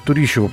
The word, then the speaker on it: Турищева: Э, Турищева, правильно, по-моему, ударение сделал.